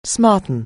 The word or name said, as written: smarten